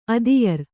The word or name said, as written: ಅದಿಯರ್